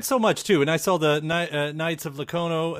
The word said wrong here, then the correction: Lono: Am I saying that right? The comic that's on the way, too? I mean, Knights of Lono. – Lono, yeah. That's right, Knights Lono, yeah.